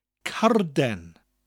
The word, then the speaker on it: carden: Now, card in Welsh is carden. It's spelt like card, C A R D, with an E N at the end of it. And when you get to the R in card, you roll it, give it that slight R sound, like you're um, like you're doing a motorbike sound.